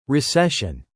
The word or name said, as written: recession